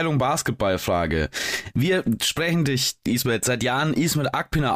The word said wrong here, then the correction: Akpinar: aus, aber wir haben irgendwann mal gelernt, dass das gar nicht die richtige Aussprache ist. Richtig für deinen Nachnamen? Ismet Akpinar. Ja, genau. Also es, äh, wir haben einen türkischen Kollegen, der sagte, der heißt Akprener.